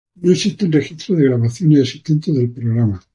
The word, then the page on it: existentes: espanhol